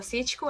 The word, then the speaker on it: Sitko: do meu Instagram é arroba Rafaela Cítico, a Rafaela com PH, Cítico S-I-T-K-O, meu nome não é muito fácil.